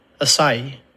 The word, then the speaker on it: acai: Acai.